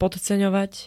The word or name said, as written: podceňovať